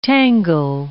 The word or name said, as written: tangle